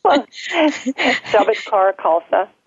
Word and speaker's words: Shabad Kaur Khalsa: Shabad Kaur Khalsa.